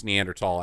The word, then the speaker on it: Neanderthal: Uncle Joe called the governor of Texas that he by repealing the mask mandate, he was acting like a Neanderthal, which is pronounced Neanderthal, actually.